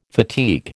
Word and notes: fatigue: American English

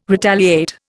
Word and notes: retaliate: Indian

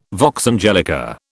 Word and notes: vox angelica: British English

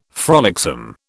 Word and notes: frolicsome: British English

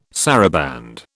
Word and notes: saraband: British English

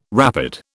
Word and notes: rapid: British English

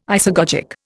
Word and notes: isagogic: Indian

